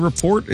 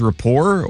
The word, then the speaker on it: report: Is the T silent in report? Is it the Steelers rapport or the Steelers report? – Steelers report. – Okay, so the T is not silent.